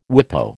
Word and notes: WIPO: American English

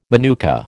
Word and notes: manuka: American English

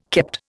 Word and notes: kept: Indian

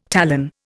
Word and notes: talon: Indian

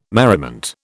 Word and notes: merriment: British English

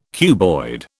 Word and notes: cuboid: British English

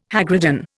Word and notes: hagridden: Indian

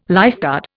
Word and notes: lifeguard: Indian

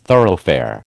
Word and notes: thoroughfare: American English

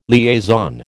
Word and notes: liaison: American English

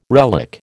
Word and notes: relic: American English

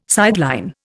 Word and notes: sideline: Indian

